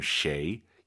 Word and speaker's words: 谁: 쉐이, 쉐이 해도 알아듣지만 정확한 발음 쉐이, 입을 가로로 쫙 찢으면서 쉐이 발음하시기 바랍니다.